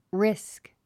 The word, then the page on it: risk: rísk; リスク